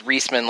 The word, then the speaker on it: Reisman: I say it's Reisman, like Reese's Pieces.